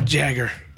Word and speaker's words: Jagger: Jagger. – Is it not Jaeger? – I mean, could be. I would pronounce that Jagger. Jagger. – It's two G's. It's Jagger.